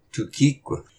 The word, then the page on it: Tohkiq: Y’all get up / wake up!